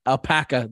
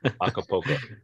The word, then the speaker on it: Acapulco: Alpaca, the original soundtrack. Acapulco.